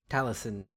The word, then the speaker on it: Taliesin: But yeah, no, our old wizard friend, Taliesin? I don't know how to say his name. – Taliesin.